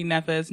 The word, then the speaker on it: Nevers: Do people spell it They pronounce it Nevers, which is already annoying. – Oh. – It's just easier when I say Natalie Nevers, never with an S on the end. They can't get it wrong.